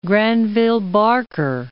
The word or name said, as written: Granville-Barker